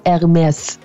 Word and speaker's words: Hermès: Ерме це чи Ермес. І завжди в нас так прийнято, знаєте, в суспільстві, що нібито правильно казати ерме, нібито «с» во французькій не, не вимовляється. Але не в цьому випадку. Я була настільки впевнена, що «с» вимовляється, що я посперечалася зі своїм приятелем на браслет шкіряний ермес.